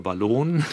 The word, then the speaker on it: Ballon: Wir erinnern uns an den Spionageballon, auch in Deutschland, oder Ballon oder Ballon. Ja, jede Aussprache ist möglich, habe ich gelernt.